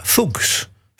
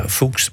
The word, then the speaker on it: Fuchs: En dan ben je heel sterk geneigd om het uit te spreken als Dana Fuchs. Fuchs. Zo schreef je het. F-U-C-H-S. Maar als je dat dan opzoekt op in Wikipedia, dan staat er keurig netjes Dana Fuchs, pronounced as Fuchs. Dana Fuchs. Nou, ik hou het toch maar op Dana Fuchs.